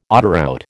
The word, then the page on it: autoroute: American English